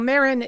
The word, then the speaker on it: Meryn: So Merin is spelled M-E-R-Y-N.